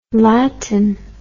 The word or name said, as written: latine